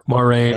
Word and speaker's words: Moiraine: Like I said, I call her Moiraine. Is that how you say it? – Yeah. Moiraine or Moiraine.